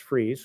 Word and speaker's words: Fries: And it's just north of the town of Freeze, spelled like fries, like French fries, but it's pronounced Freeze. So don't make that mistake when you visit.